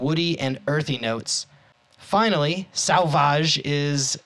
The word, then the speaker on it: Sauvage: This is the Nola Brewing Sauvage. Sauvage? S-A-U-V-A-G.